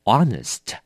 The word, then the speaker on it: honest: 我们读的时候啊是没有读出来的哦，所以只有 honest。虽然拼了 H，但是读的时候这个 H 是不发音的。